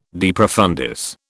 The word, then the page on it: de profundis: British English